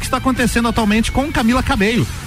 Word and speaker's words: Cabello: cabelo, a pronúncia correta, né? Camila Cabelo. Cabello.